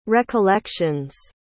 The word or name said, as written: recollections